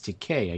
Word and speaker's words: Takei: And then when he came out, I think he corrected everybody and said, it's Takei. I guess that's the way they pronounce it in Japan.